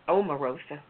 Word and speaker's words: Omarosa: I'm speaking on Amorosa. Is that how you pronounce her name? – Omarosa.